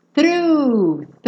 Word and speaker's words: through: Through, through. Não se preocupem com esse GH no final, tá? Ele é mudo.